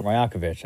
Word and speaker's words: Rajakovic: Darko Rajakovic. – Rajakovic.